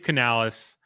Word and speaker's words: Canales: Canales? And I don't even know if I'm saying that right either. – Yeah, Dave Canales.